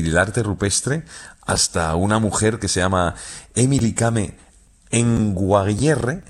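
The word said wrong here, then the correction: Emily Kame Kngwarreye: lo he dicho mal. – Emily Kame. – Con Guarreye. – Emily Kame con Guarreye, sí.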